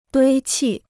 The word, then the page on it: 堆砌: duī qì